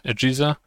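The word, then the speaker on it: AGESA: EGISA, which stands for AMD Generic Encapsulated Software Architecture, pronounces somewhat like PISA, EGISA.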